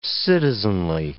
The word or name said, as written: citizenly